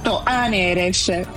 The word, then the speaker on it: Aneres: invece è Serena al contrario, giusto? – Esatto, Aneres. – Aneres, sì, sì. Aneres, quindi tu vai con l'accento sulla E.